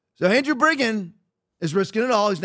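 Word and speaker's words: Bridgen: Hope I'm pronouncing it right because it's B-R-I-D-G-E-N, but I've heard it pronounced Briggen, so I hope that's it, not Bridgeton.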